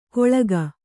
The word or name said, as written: ಕೊಳ್ಕ